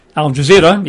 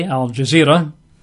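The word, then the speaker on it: Al-Jazira: In fact, in Arabic, most Arabs and Muslims call Arabia Al Jazeera, Al Arabia. Not sure you pronounce that. Al Jazeera, easy enough, A-L hyphen J-A-Z-I-R-A, Al Jazeera, and then Al hyphen Arabia.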